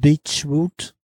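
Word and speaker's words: Beachwood: Beochwood, somebody was like, what did you say? And I was like, am I saying it wrong? – It's pronounced Beechwood. Long Beach, Lakewood, Beechwood.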